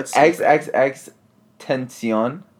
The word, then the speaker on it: XXXTentacion: Yeah, XXXTentacion.